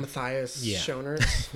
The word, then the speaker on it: Matthias Schoenaerts: Matthias Schoenaerts.